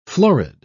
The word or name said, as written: florid